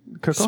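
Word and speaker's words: Kraków: Just for Krakow. Krakow. Spelled like Krakow. Like Krakow. – Okay. – But not spelled like that at all.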